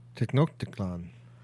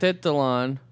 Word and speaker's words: Tenochtitlan: Tenochtitlan. – Tenochtitlan. I can't remember how to say it. Tenochtitlan. – Tenochtitlan? – Tech. Say it with me. – Ten. It's no tech. It's ten.